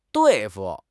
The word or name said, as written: 对付